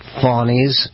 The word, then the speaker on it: Fani: Fawny, Fawny. It's spelled Fanny. It's spelled Fanny like your ass, right, Fanny? But when she became DA, she decided to add a little French, a little fancy. Fawny. Fawny and... – Okay, her name is spelled F-A-N-I, by the way.